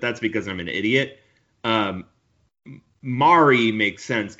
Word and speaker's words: Mari: So her name is Anna, but it's A-N-A. And then it's M-A-R-I. It's pronounced like calamari, Mari. Like, so Anna Mari, and it's Kause, her last name.